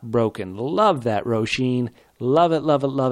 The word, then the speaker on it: Roisin: And now let me say this correctly. Roisin, a.k.a. Rosie the Clever, a.k.a. at Roisin Gleason on Twitter. That's R-O-I-S-I-N Gleason on Twitter.